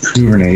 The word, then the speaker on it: Duvernay: That guy, was Duvernay? Is that how you pronounce it? – Duvernay, yeah.